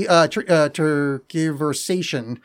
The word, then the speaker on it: tergiversation: So the pronunciation key is TERGIV-ER-SAY-SHUN. But the spelling is TERGIVERSATION.